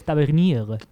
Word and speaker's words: Tavernier: Tavernier, creo que se pronuncia. – Es francés, Tavernier. – Ah, Tavernier.